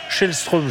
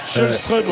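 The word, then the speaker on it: Källström: mais ça me fait penser à Kim Karlström, où il fallait dire aussi Schellström, je crois. – Schellström, ouais.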